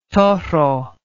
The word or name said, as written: toro